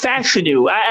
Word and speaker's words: Fashanu: I only learned this about a month ago. It's Fashanu. It's Olufoshanu.